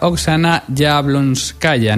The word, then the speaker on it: Oksana Yablonskaya: Oksana Yablonskaya. No sé si lo he dicho bien, Laia, buenas tardes. – Hola, buenas tardes, sí, perfecto.